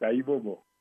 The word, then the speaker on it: Ayibobo: Which is Ayibobo? – There you go. That's it. Ayibobo? Is that how you say it? – Yeah, correct. Ayibobo.